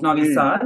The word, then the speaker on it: Novi Sad: I think it would be the maps of my hometown of Novi Sad.